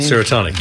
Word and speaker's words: Serotonic: Is that how you pronounce the name? – Serotonic, yes. – Yes, a Serotonic band. Like the word serotonin, yes.